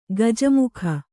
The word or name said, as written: ಕುಜ್ಜಮುಖ